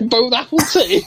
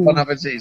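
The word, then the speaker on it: bon appétit: Yeah, so instead of bon appetit, it's from bone apple tea.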